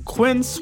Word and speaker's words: Quince: Today Explained's support today comes from Quince, which rhymes with since, but is spelt with a Q-U.